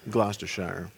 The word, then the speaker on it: Gloucestershire: How is it actually pronounced? Gloucestershire? Gloucestershire. Yeah, that's it.